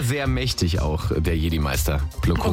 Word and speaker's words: Plo Koon: Plo Koon. – Plo Koon. – Plo Koon. P-L-O, neues Wort, K-O-O-N.